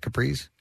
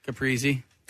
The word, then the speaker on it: Caprese: Is that how you say that, Caprese? – Caprese.